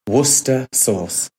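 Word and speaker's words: Worcestershire sauce: Worcester Sauce. – Aber das wird doch ganz anders geschrieben. Ja, aber es ist Worcester Sauce.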